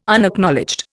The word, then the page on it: Unacknowledged: Indian